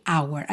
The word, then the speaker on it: our: E esse possessivo aqui, our, que é o nosso, nossa, nossos, nossas, geralmente, quando a gente vai procurar, por exemplo, no dicionário, qual é a pronúncia de our? A gente tem our, bem desenhadinho, our. Não é assim que ele aparece muitas vezes, aparece R, R, como se fosse a letra R.